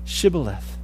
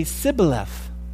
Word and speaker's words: Shibboleth: And the problem was they couldn't pronounce sh. They would say Sibboleth.